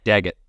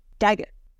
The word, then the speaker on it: Daggot: D-A-G-G-O-T. How did they pronounce it in the audiobook? – Daggett. – Daggett. Perfect.